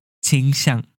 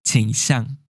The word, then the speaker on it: 倾向: 这里要注意一下，虽然倾向的倾正确的发音是一声倾倾向，但是台湾人都习惯念三声倾倾向。